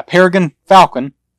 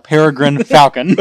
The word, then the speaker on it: Peregrine Falcon: A Peregrine Falcon. I didn't say that right. This is a bird. A Peregrine Falcon.